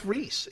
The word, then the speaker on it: Rhys: It is pronounced Rhys. It is pronounced Rhys.